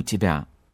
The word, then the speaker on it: тебя: When you say I love you in Russian, Я люблю тебя, it is this reduced sound at the end again.